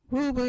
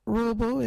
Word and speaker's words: Ruubu: Our local Wagyu word is Ruabu. What is a Ruabu? Ruabu is a word for rope. You can use Ruabu in this sentence. Get that Ruabu. Ruabu is spelt R-U-U-B-U.